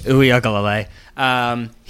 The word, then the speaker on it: Uiagalelei: and of DJ Ugal. I'm going to mispronounce. I'm going to butcher it. Go ahead. – Uiagalelei.